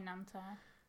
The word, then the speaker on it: Nanterre: Suburb, 7 miles to the northwest, called Nanterre. – Aww. – I don't know if that's how you pronounce it. Nan, Nanterre. – I'd say Nanterre.